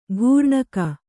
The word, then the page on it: ಘೂರ್ಣಕ: kannada